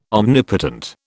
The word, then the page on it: omnipotent: British English